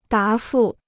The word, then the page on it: 答复: dá fù